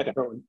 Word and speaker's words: Idahoan: So man, growing up in Idaho, growing up on the river, like, this is your, you know, fifth generation Idahoan. Did I say that right? – Yeah. Idahoan.